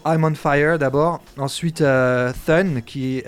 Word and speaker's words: Thun: je me demandais ce que ça voulait dire, ce t u n Et en fait, c'est une référence à Son, Son le fils. – C'est leur, leur prononciation de Queensbridge. – En okay. fait, le THUN, prononce comme ça.